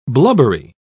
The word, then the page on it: blubbery: British English